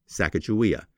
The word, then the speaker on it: Sacajawea: But getting back to her name, her native tribe, the Shoshone, pronounced her name the way I learned it, Sacajawea.